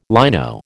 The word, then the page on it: lino: American English